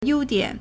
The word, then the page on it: 优点: yōudiǎn